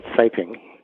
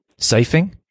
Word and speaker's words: siping: That's called siping. – Siping? – Siping. – Oh, siping.